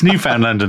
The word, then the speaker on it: Newfoundland: We discovered, how do you say it? Newfoundland. – Oh, God, don't even get just it's Newfoundland and that's an end to it.